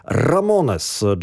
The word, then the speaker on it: Ramones: Jak to się wymawia? Punkowcy zawsze mówili po prostu Ramones.